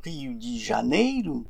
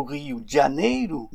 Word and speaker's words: Rio de Janeiro: Pour le prononcer correctement, il faudrait dire Rio de Janeiro ou Rio de Janeiro, mais non pas Rio de Janeiro.